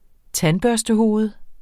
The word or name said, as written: tandbørstehoved